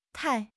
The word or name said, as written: tai4